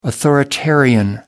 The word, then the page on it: authoritarian: American English